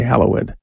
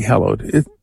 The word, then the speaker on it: hallowed: We say hallowed. The usual pronunciation would be hallowed. We use an older pronunciation in the Our Father because, well, that's how language happens.